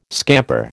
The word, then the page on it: scamper: American English